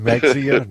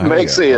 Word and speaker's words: Mexia: Mahia, or Maher, however you pronounce it, Maher. – Uh-huh. Then, where is that? Yeah. – You mean Mexia? No, it's my Mexia.